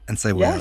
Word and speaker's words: Encebollada: Carne frita, and let me see if I can pronounce this correctly. Encebollada. – Yes. – Encebollada. – Yes, you said it right. That's perfect.